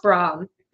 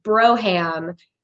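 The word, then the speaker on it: Brougham: But I mean, like, so there's like lots of these stories because so the RV was named Ham, and because it's pronounced Brom, but it looks like Broham.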